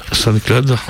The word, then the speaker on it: SoundCloud: Vous pouvez l'écouter sur SoundCloud. SoundCloud, ou comment ça se dit? – SoundCloud.